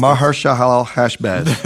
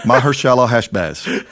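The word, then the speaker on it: Maher-shalal-hash-baz: Then the Lord said to me, take a large tablet and write on it in common characters belonging to, I'll let you try and pronounce it. – Maharshalal Hashbaz. – That's great.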